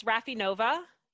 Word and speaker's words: Rafi Nova: It's Raffi Nova.